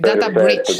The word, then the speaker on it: data breach: Data branch. Guarda, breach, per carità del cielo, perché c'è un ascoltatore che ci sta tormentando da giorni, sgridando perché pronunciamo male. Data breach.